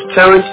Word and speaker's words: Tarrance: My name is Terrence. You spell it T-A-R-R-A-N-C-E. King. K-I-N-G.